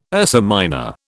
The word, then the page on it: Ursa Minor: British English